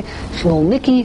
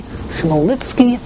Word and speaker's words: Chmelnytsky: There are different ways to pronounce it, the Yiddish way and the second way, Chmelnytsky, Chmelnytsky. And the estimate, this took place, of course, in Poland.